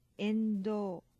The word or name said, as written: endou